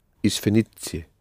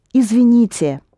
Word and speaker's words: Извините: Извините. – Извините.